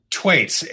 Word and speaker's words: Thwaites: Ken Thwaites, am I getting Thwaites right, Ken? – Twaits, H is silent.